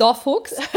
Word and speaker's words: Dorfuchs: Okay, also aus Sachsen kommt Dorfuchs. Statt der wird eben Dor geschrieben.